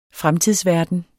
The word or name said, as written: fremtidsverden